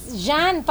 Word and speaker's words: Zhaen: Si Zian Alea. Jean pala ang pronunciation ng name niya. – Jean. – Jean. Z-H-A-E-N.